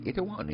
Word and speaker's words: Itaewon: Itaewon is an interesting area that's spelled I-T-A-E-W-O-N, Itaewon.